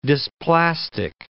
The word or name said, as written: dysplastic